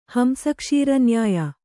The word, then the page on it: ಹಂಸಕ್ಷೀರನ್ಯಾಯ: kannada; hamsa kṣīra nyāya